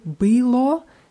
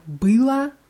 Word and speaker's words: было: Bei была ist die ganz deutlich auf a und у меня было много работы. Было ist auf ü, weil äh, sonst, wenn man было auch auf die letzten Silbe betont, dann hört es sich wie была an. Было, была.